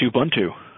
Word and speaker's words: Ubuntu: Shouts to Chris for sending me that Ubuntu pronunciation. Ubuntu. I need to start reading that because I'm still using. Oh, it's Ubuntu, but whatever.